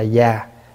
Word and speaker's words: giá: Cũng giống như trong Phật giáo có cái tánh giới, hay là giá giới vậy đó. Có khi đọc là giá, có khi đọc là già, cái nào cũng được.